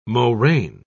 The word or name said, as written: moraine